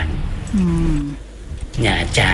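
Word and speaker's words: Nha Trang: หยาจางนะฮะถ้าเห็นเป็นภาษาอังกฤษจะอ่านว่านาตรังนะฮะแต่จริงๆอ่านว่าหยาจาง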